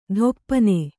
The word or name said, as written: ಥೊಪ್ಪನೆ